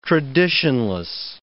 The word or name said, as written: traditionless